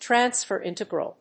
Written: transfer integral

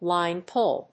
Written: line pull

